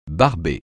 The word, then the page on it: barber: francais